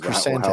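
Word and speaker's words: Krrsantan: Now I don't know how you pronounce this. Black Krisantan. – There you go. – Krisantan. Yeah. Kirsantan. – Kirsantan is Krisantan. How I've heard it pronounced.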